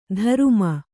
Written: ಧರುಮ

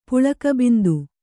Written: ಪುಳಕಬಿಂದು

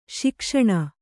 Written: ಸುಖಾಸನ

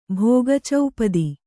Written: ಭೋಗಚೌಪದಿ

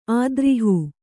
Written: ಅದ್ರೋಹ

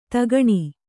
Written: ಧೊಕ್ಕನೆ

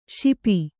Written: ಶಪ್ಪ

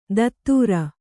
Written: ಧತ್ತೂರ

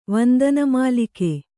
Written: ವಂದನಮಾಲಿಕೆ

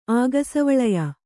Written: ಆಗಸವಳಯ

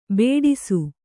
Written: ಬಡಿಸ

